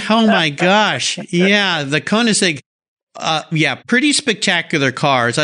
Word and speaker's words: Koenigsegg: The guy's name is Christian von Koenigsegg. – Koenigsegg, yes, Koenigsegg, yes.